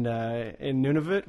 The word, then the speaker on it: Nunavut: It's like, did we talk about it on the last radio show where our prime minister put an extra U in Nunavut? Was it Nunavut? – Yeah, our other radio show.